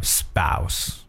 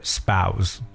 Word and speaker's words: spouse: 接下来和大家分享的一个词呢，叫 spouse or spouse，好，有两种不同的这个读音呢，这个后面是 s。或 z 两种读音都可以。